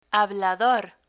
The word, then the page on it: hablador: Spanish